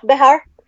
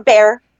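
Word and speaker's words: Bexar: Yeah, Bihar. Yeah. Or Bear. You can say Bear, because that's the common way to say it. But it's Bihar. It's a Spanish, yeah.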